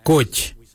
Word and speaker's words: goodj: Garli is spelled G-I-L-Y-I, garli. For the traditional stone axe, we say gouge, gouge. Gouge is spelled G-O-O-D-J, gouge.